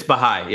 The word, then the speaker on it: Baha'i: He comes from the Baha'i faith. Is that how you pronounce it? – It's Baha'i. Yep.